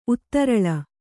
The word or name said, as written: ಉತ್ತರಳ